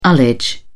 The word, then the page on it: ullage: British English